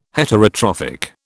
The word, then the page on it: Heterotrophic: British English